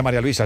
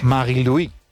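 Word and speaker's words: Marie-Louise: Bueno, no se dice María Luisa, se dice en su... – Sí. – Marie-Louis. – Marie-Louis Eta.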